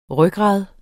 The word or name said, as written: rygrad